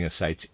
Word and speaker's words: ascites: When the patient came to us, she had a congestive abscess in the right thigh, bloated abdomen with slight ascites, and catarrhal sounds over the apices of both the left and the right lung. Readers aside, I'm pronouncing ascites A-S-C-I-T-E-S. Forgive my pronunciation there. Ascites.